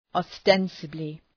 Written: ostensibly

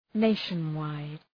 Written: nationwide

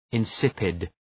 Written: insipid

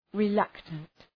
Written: reluctant

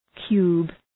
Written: cube

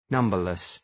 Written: numberless

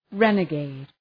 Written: renegade

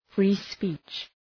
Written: free-speech

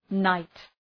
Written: night

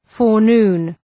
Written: forenoon

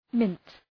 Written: mint

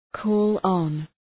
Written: call on

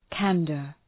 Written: candour

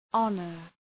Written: honor

